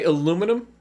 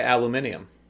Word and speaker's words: aluminium: I say aluminum, but I respect those who say aluminium. – You do respect the alum. – I do. – Okay. That's the way they pronounce it, over in Barcelona. when I was over there, I don't know if I mentioned that I was over on the continent in Spain. – You brought that up once or twice. – Yeah, and they pronounced it aluminium.